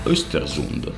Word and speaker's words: Östersund: Perché si chiama Östersund.